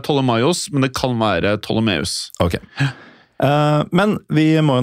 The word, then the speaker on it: Ptolemaios: Uh, det skrives P-T-O-L-E-M-A-I-O-S. Den tolvte. Jeg har forstått det sånn at det uttales Ptolemeus. – Ja, Ptolemaios. – Ptolemaios, den tolvte.